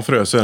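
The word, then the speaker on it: Froese: Hur uttalar du det på tyska? Det efternamnet. – Ja, Fröse är det.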